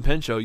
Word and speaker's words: Pinchot: On Bronson Pinchot, you don't pronounce the T.